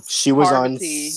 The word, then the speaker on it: Parvati: Some of the people, I call her Poverty. Like you live in poverty, but that's not her name. – It's Parvati.